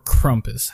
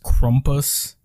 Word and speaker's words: Krampus: So I think I vaguely mentioned this, but there's a Christmas character called Krampus, or Krumpus, however you want to pronounce it. I think Americans say Krampus, and I believe it's supposed to be pronounced Krumpus.